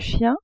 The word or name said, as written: chien